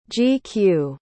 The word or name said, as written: gq